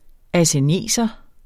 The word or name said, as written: asseneser